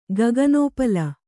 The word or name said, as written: ಗಗನೋಪಲ